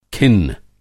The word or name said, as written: खिन्न